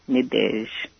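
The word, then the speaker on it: Nadège: How do you spell that? N-A-D-I-J? – N-E-D-E-G-E, Nadege.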